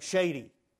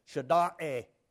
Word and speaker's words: Shaddai: Now, God Almighty is the God's Elohim, Almighty, in the Hebrew is, when you look at that, S-H-A-D-D-Y, you're going to think shady. No, that's not the way you pronounce it. Shada'e, or Shada'e.